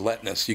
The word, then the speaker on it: Letness: too because his last name is spelled L E T N E S. He goes Letness. You can't forget it because it's just basically Nestle all scrambled up.